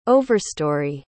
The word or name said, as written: overstory